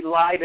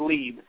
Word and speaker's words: Lieb: My last name's spelled L-I-E-B. Everybody calls me Live, and it's pronounced Leave.